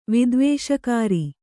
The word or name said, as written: ವಿದ್ವೇಷಕಾರಿ